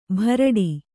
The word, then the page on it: ಭರಾಟಿ: kannada